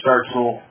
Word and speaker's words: Startzell: My last name is spelled R-E-M-U-S. Gotcha. Last name start can you yours? Sure. Uh, Lieutenant Colonel James Startzell. Last name is spelled S-T-A-R-T-Z as in zebra, E-L-L.